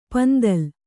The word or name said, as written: ಪಿಂತಿಲ್